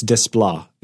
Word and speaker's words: Desplat: It's Desplat. It's not Alexander Desplat. – It's Desplat. Who says that? Movie guy the the 365 movie guy, Clark Douglas. – He told me that you could softly pronounce the T.